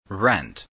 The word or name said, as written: rent